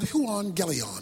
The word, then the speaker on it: euangelion: The Greek word for gospel is the word euangelion. That's how it's pronounced.